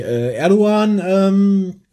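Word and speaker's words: Erdoğan: Ist aufgefallen durch die Erdogan- das Erdogan-Schmähgedicht. – Das ist sehr gut ausgesprochen, weil das G spricht man im Türkischen nicht aus, wenn es so einen Strich hat.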